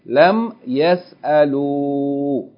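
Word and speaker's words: Lam yas'alu (لم يسألوا): But what's not pronounced here is the aleph that's written after it, okay? Lam yas'alu.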